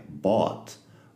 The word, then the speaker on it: bought: La conjugación de buy es buy, bought Bought. Fíjate que se escribe con GH en medio, pero no se pronuncia la GH.